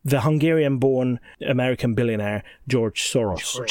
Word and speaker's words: Soros: Initially, I was thinking it was Angela Merkel smuggling people in the back of her limousine, but no, should have known it's Soros. – No, even Angela Merkel is in the pocket of George Soros, should be called Soros, by the way, because that's how we pronounce it in Hungarian.